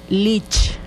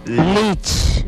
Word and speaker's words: Lich: Chiquihuau en yocotán se dice Lich. – Lich. – Lich. Y se escribe de esta manera. L. – L. – I. – I. – C. – C. – H. – H. – Lich.